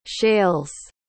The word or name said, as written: shales